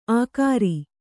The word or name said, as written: ಆಖರಿ